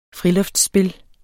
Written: friluftsspil

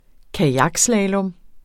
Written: kajakslalom

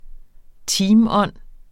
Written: teamånd

